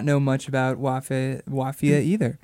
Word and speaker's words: Wafia: Wafia. – Wafia. – Which rhymes with mafia. – Rhymes with mafia, spelled like mafia, but with the W.